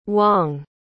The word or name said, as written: wang